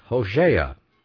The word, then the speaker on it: Hosea: The name O-S-H-E-A, or Oshea, is the same as Hosea, H-O-S-E-A, or H-O-S-H-E-A, either way, pronounced Hosea, Hosea, which means deliverer, or salvation.